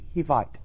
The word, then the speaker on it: Hivite: Hivite. H-I-V-I-T-E. Hivite. I's are pronounced as long E's. Hivite. In Hebrew, it would be Hivi.